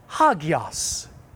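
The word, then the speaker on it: hagios (ἅγιος): And we pronounce it hagios.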